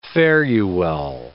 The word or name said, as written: fare-you-well